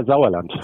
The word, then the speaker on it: Sauerland: I want to get on um, soon to talk about um, the Sauerland group, if I pronounced that correctly. – Yeah, Sauerland. – Sauerland, right? It's a soft S.